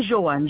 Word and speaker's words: Joan: Martin Johan. Johan, ça s'écrit J-O-A-N.